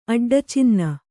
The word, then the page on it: ಅಡ್ಡಚಿನ್ನ: Kannada